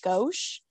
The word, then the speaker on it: Gosch: However, his last name is spelled G-O-S-C-H, which I feel like would be like Gosch, but I Google pronounced it and Google told me Gosch, so I can't argue with Google.